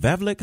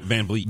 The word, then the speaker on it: VanVleet: And I think it was, is it Vavlik? How do you say his name? The point guard who got his teeth knocked out. – VanVleet.